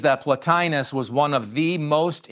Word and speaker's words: Plotinus: It's pronounced Plotinus, P-L-O-T-I-N-U-S, Plotinus.